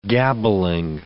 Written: gabbling

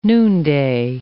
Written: noonday

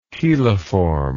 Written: cheliform

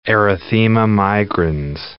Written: erythema migrans

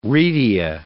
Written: retia

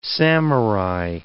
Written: samurai

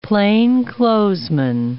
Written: plainclothesman

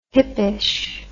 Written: hippish

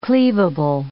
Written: cleavable

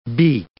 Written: beak

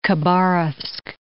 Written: Khabarovsk

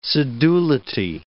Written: sedulity

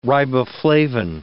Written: riboflavin

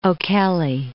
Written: O'Kelly

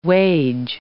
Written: wage

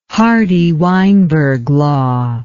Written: Hardy-Weinberg law

